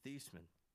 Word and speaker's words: Theismann: It's actually pronounced Theismann. But, and you know, they wanted his, he was going for the Heisman.